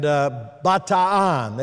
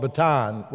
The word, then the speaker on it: Bataan: And Bataan, they pronounce both their vowels. We call it Bataan, where the Bataan Death March was.